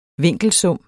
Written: vinkelsum

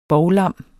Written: bovlam

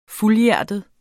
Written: fuldhjertet

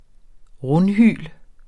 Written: rundhyl

